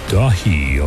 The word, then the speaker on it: Daithi: from Elyon called Dahi, although it's spelled D-A-I-T-H-I.